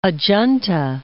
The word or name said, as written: Ajanta